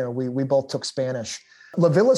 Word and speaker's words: La Villa: Is it La Villa or is it La Via? – I think the correct pronunciation in Italian is La Villa.